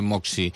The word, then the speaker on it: Moxie: Moxie, si ho busqueu, s'escriu Moxie, Moxie, es pronuncia Moxie.